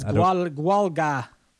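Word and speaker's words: Gaeilge: It's Gwalga. – Gwalga. That's how you say hello in Irish. – No, Gwalga is how you pronounce Gaelic. – The Irish language is sometimes referred to as Gwalga. It's spelled Gaelge.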